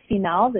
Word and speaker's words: FeMails: So the podcast is called The Females. It's spelled F-E-M-A-I-L-S. So we tried to get clever and really mix the word female and email, since it's a podcast that's about women and work.